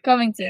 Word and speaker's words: Covington: Covington.